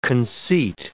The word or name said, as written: conceit